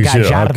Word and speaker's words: Gallardo: I heard that you Gallardo. – Should. Okay, come on. Here we go. – The double L is pronounced like a J there.